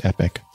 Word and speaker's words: Epoch: Oh, it is pronounced epic.